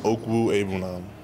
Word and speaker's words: Okwuegbunam: Yeah, it's pronounced Okwuegbunam. It's all together Okwuegbunam.